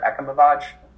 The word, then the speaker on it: Ekimovich: It's Esimovich or Ekimovich? – Ekimovich. – Ekimovich. I like that. – The V-I-C-H is usually a Vich. So, Vich, not... I believe it's Ekimovich.